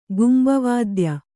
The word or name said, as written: ಕುಂಭವಾದ್ಯ